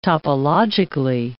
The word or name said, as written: topologically